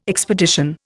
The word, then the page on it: expedition: Indian